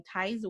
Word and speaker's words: TiZE: Did I say it correctly? TiZE. TiZE. That's correct.